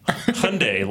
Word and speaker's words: Hyundai: I think it was like Jeff Bridges, and he said, it's like Sunday. Hyundai, like Sunday.